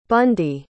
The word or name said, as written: bundy